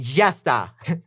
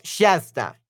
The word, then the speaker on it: ya está: ya, no, es ya. Ya está. This is so hard. How do you say that? So easy. Ya, eh, ya está. Okay, y ya está is that's it in Argentinian accent. Ya está. Oh, Shasta, Shasta.